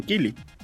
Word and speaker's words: Keighley: Es que se escribe raro porque parece que se tenga que decir Keighley, pero en toda la presentación dicen Keighley.